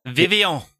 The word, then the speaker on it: Vivillon: I was thinking Vivian or Vivillon, however you pronounce that. – Vivion. – Vivion. Vivion. – Il est Vivion. – It is Vivion? – Oui.